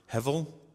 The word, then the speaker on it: hevel: A bumper video that we play before each sermon touches on the word hevel. It's spelled with a B, but it's pronounced with a V.